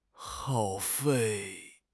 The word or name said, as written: 耗费